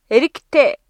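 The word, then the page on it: эрэгтэй: Mongolian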